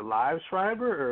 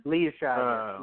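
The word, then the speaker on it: Liev Schreiber: But is it is it Live Schreiber or is it Lee Schreiber? – Lee Schreiber. Uh, Lee Schreiber. Okay. Lee Schreiber. Yeah.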